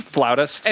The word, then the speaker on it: flautist: How many women a year do you think are like, so I'm fucking this flutist? – Actually, it's pronounced flautist. – It's flautist, you idiot. It's a flautist.